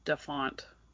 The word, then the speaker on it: DaFont: The only website I know of is DaFont. Isn't that how it's pronounced? – Yes, it is.